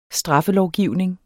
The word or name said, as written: straffelovgivning